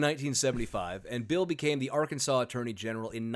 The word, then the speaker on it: Arkansas: Okay, it's the French fault. It's the fault of the French. Because... – Well, everything is the French's fault. We know that. – Of course, but it's spelt the same way, Kansas, Arkansas. But you think about how the French would pronounce Kansas. Mm-hmm. It would be Kansas. So it's Arkansas.